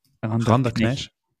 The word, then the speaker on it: Rinderknech: Wie spricht man ihn eigentlich richtig aus? – Rinderknech.